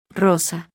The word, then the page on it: r: Spanish